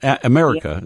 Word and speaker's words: America: America is how you say her name.